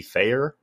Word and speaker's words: Fehér: But we are talking about Twilight, 1990 film, directed by Georgi Feyer. Is that how we think that's pronounced? Probably. – I'm okay with that.